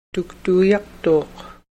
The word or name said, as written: tuktoyaktuk